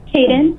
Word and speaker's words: Kayden: Caden. – Can you spell it? – K-A-Y-D-E-N.